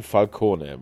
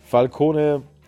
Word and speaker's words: Falcone: Carmine Falcone oder Carmen Falcon, wie immer man ihn aussprechen will. Falcone, denke ich mal, ist das, worauf ich mich jetzt hier festlegen würde, da er doch ein bisschen italienisch hier rüberkommt.